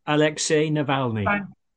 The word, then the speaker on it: Alexei Navalny: Alexei Navalny.